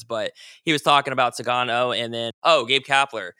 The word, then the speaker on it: Sugano: Tomoyuki Sugano? – Sugano's right. – Sugano.